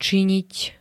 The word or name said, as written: činiť